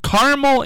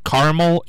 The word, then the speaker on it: Carmel: So Carmel, Indiana is right. That's how you would say it. Carmel, Indiana. But caramel, C-A-R-M-E-L, is never a food. The food is C-A-R-A-M-E-L, which you would pronounce caramel, not caramel.